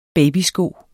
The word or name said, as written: babysko